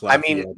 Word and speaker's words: Lafayette: That's not how you pronounce Lafayette in Louisiana. – Yeah, it is. Lafayette. If you grew up in Lafayette, you know it's Lafayette. If you say anything else, then we know you're not from there.